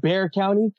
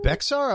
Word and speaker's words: Bexar: It's pronounced Bear County. – Bear, yes. Sorry, it's okay. No worries. – It's Bear one of those. My wife was like, "It's not Bexar."